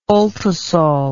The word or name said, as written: ultisol